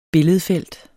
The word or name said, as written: billedfelt